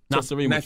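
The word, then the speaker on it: Nacirema: I like this follow-up because, you know, Nakarama Dream. – Nasarima. – You know, I can't pronounce that. – Say American backwards. – Yeah. Nasarima Dream took a while to come out, but you came right back with the sophomore project.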